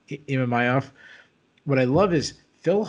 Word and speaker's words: Imavov: How do I say his last name? – Imavov. – Imavov. That's right.